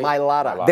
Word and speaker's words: Mailata: Mailata.